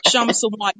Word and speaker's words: Sawant: Shama Sawant. Yeah, the K is silent.